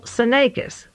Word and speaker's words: Fthenakis: is Emmanuel Senakis. I'll spell it. You would never guess it. F-T-H-E-N-A-K-I-S, the director from Los Altos, California.